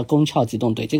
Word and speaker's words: 攻壳机动队: 它的官方说正确发音叫宫壳机动队，这个无所谓。